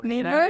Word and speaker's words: El Niño: in the L Nino, Nino. Yes, as I, I can yes, pronounce. I officially correct.